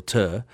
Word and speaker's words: pter: It's actually helico, as in spiral, and pter, P-T-E-R, silent P, so ter, as in the start of pterodactyl.